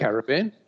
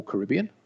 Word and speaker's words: Caribbean: Caribbean. Yes. Yeah. Uh, or Caribbean, depending on where you want to uh, get your, your pronunciation from.